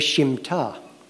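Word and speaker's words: Shimta (שמיטה): but she told me the pronunciation is the Shimta.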